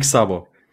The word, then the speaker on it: Szabo: Nick Szabo.